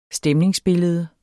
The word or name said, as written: STEMNINGSBILLEDER